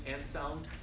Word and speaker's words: Anselm: Anselm.